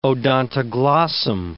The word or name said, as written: odontoglossum